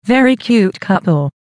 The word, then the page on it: very cute couple: American English